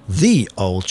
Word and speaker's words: Ye Olde: Or as it should be pronounced, The Old Cheshire Cheese. – Indulge us a moment in Pedant's Corner to explain this. – Ye Olde is a pseudo-anachronism. – The Y in ye is a substitute for the old English letter thorn, which disappeared from English about 500 years ago to be replaced by the TH sound.